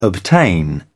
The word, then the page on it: obtain: British English